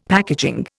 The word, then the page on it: packaging: Indian